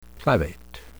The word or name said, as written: clavate